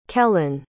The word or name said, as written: kelyn